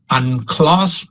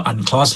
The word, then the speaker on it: UNCLOS: UNCLOS บางสื่อมวลชนทั่วไปจะอ่านว่า UNCLOS